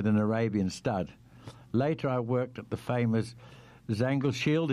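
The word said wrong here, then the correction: Zangersheide: Is that how you pronounce that? – Zangersheiden.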